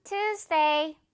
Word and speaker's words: Tuesday: 역시 유 발음 아니고 우 발음으로 갑니다. 화요일에 봐. See you on Tuesday.